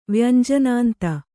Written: ವ್ಯಂಜನಾಂತ

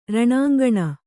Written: ರಣಾಂಗಣ

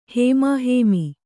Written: ಹೇಮಾಹೇಮಿ

